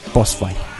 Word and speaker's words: Posfay: Guillermo, como cualquier Guillermo, D-E, y separado, Posfay, P-O-S-F-A-Y. – Bien. – Guillermo D. Posfay, con acento en la O, Posfay.